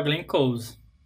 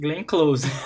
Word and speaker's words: Glenn Close: Que é com a Glenn Close. Glenn Close, olha eu falando errado, gente. Glenn Close.